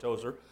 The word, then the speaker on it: Tozour: My family name is spelled T-O-Z-O-U-R. Pronounced the same, Tozer, and found out we all come from the same tribe of people in England.